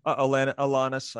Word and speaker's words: Alanis: Am I saying her name right? – Alanis, I think. – Alanis. – Interchangeably. – Alanis Morissette. – I don't know her personally, so, you know, I hear it both ways.